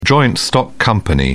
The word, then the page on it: joint-stock company: British English